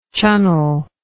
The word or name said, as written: Chunnel